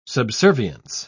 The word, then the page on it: subservience: British English